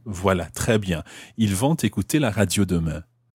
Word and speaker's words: vont écouter: Ils vont écouter la radio demain. Why am I pronouncing the T of ils vont? – Because écouter starts with a vowel.